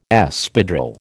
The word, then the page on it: Espadrille: American English